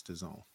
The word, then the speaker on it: DAZN: DAZN. DAZN. I don't think anybody knows how to actually pronounce that, but I promise y'all it's DAZN.